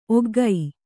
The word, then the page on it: ಒಗ್ಗೈ: oggai